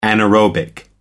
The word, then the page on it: anaerobic: American English